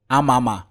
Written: AMAMA

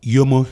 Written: Yomo

